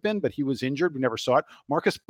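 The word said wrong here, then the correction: Marques: Bolden isn't, Marquise Bolden. I was corrected on his pronunciation of his name.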